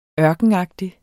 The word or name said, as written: ørkenagtig